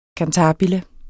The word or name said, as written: cantabile